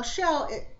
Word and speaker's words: Chel: Now, Shell, you know, it sounds like a seashell when I say her name. But her name is spelled C-H-E-L.